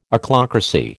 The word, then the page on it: Ochlocracy: American English